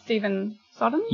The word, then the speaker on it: Stephen Sondheim: Well, I mean, that's Stephen Sondheim.